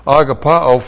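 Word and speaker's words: agapao (ἀγαπάω): The verb is A-G-A-P-A long O, agapao. From the verb, we get the noun agape. A-G-A-P long E, and both of them are words that describe and deal with virtue love.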